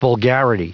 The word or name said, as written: vulgarity